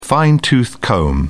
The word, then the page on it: fine-tooth comb: British English